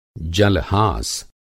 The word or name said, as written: जल-हास